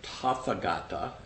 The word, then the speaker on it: Tathagata: So that word, Tathagata, or Tathagata, as it's phonetically spelled, is one of the many honorific titles of the Buddha.